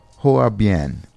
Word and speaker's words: Hoa Bien: Hoa Bien. H-O-A-B-I. And I see, I didn't pronounce it right. B-I-E-N. Bien. It's not Spanish. It's Vietnamese.